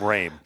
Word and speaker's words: Roehm: I think there may be like three people in my life who've actually pronounced it correctly. Actually, my family doesn't even pronounce it correctly. It's actually pronounced M. It's German, but nobody's got that tongue thing in West Kentucky, so somehow it became Roehm.